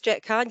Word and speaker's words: Kahane: And there's an important person you talk about, Jack, if I'm pronouncing the name correctly, Kahan. – I think it's Jack Kahan. Yes, for sure.